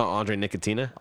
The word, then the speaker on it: Andre Nickatina: Andre Andre Arcatina? Oh, Andre Nickatina? Ar- Andre Nickatina.